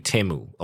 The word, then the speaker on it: Temu: T-E-M-U, Temu.